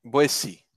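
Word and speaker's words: Boétie: Boeci. Então, Boeci que se escreve Boeti, caso você procure aí no, no Google, né?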